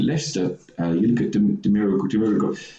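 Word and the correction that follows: Demarai: How do you pronounce it? – Demari Gray. – That's the one.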